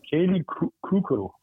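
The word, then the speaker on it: Kaley Cuoco: Hvordan udtaler du det? Kaylee Kuko? – Kaylee Kuko.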